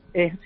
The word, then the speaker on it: Ella: Bueno, es Ella. O Ella. Escribe Ella, pero es Ella.